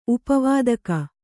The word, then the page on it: ಉಪವಾದಕ: upa vādaka